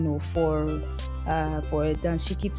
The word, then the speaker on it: dashiki: it's actually pronounced as Danshiki. That's D A N S H I K I. But some other people just type it as D A S H I K I dashiki.